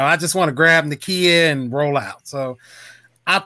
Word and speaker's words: Nakia: Sorry if I say it wrong, but Nakia, correct? Or Nakia? – It's Nakia, yeah. – Nakia.